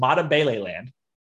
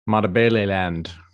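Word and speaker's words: Matabeleland: This is Matabele land. – Matabele land.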